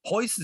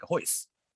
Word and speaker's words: Royce: And Hoist is Royce, right? And if you know anything at all about the martial arts in Portugal, you got to pronounce it like Hoist.